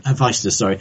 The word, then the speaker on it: Hephaestus: But he doesn't say, "would you kindly," until you get to Hephaestus, is it pronounced? – Hephaestus.